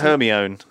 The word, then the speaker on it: Hermione: Hermione.